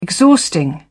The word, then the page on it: exhausting: British English